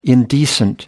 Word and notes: indecent: American English

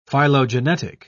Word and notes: phylogenetic: British English

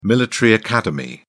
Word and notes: military academy: British English

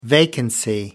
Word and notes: vacancy: American English

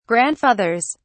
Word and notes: grandfathers: British English

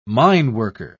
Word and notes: mineworker: British English

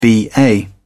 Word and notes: ba: British English

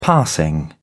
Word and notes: passing: British English

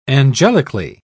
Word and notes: angelically: British English

